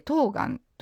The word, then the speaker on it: 冬瓜: まあ、一番面白いのは、まあ、よく皆さん冬の瓜と書いて冬瓜と読むお野菜ありますよね。